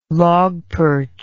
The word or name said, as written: logperch